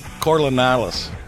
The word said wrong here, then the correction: Coriolis: There's no N in there. – There it is. – Still can't even say it right. Coriolis.